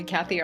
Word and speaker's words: Cathey: If you spell my name right, I'm the only Cathey Armillas in the whole world.